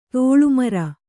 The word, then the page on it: ತೋಳ್ಮುರಿ: tōḷmuri